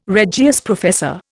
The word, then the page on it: Regius professor: Indian